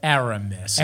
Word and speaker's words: Aramis: How do you say it? – Aramis. – Aramis? – You said it right, but fucking Aramis? – Aramis.